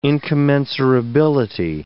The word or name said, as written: incommensurability